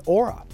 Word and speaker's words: ORA: NYC's O'Blaney Rinker Associates rebrands to ORA, pronounced Aura, reshaping the architectural lighting design landscape with a new logo and website.